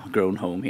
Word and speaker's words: Grown Home: Och du spelar det här spelet Grown Home. Uttalar jag det korrekt? Det känns som jag frågar en så här utländsk dignitär som kommer in. Så här, ursäkta, uttalar det där korrekt? – Ja, Grown Home heter spelet.